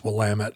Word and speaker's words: Willamette: Oh, no, it's Willamette.